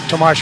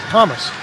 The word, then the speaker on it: Tomas: Back in the day when Tomas Hertl was just a rookie, he came up and told Randy Hahn and I that he'd like his name pronounced Tomas, not Thomas.